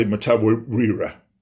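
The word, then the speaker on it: Mtawarira: What a tribute to Tendai Matawira.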